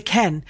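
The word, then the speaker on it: Kean: I got told it was Moses Ken because it's a version of Moses with a different pronunciation, and Ken was just how they pronounced it. But then he's come here and said, no, I'm happy to be Moise Keane. So I don't think it's actually how you say his name.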